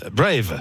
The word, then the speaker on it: Brave: Brave, voilà. – Peut-être. C'est prononcé à la française pour qu'on comprenne bien.